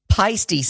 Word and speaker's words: Paiste: He plays, and tell me I'm pronouncing this right because I've said it 132 times, Paiste cymbals.